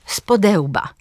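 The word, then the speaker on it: spode łba: Na przykład powiemy patrzeć z podełba. Akcent pada na drugą sylabę przyimka. We dnie i w nocy.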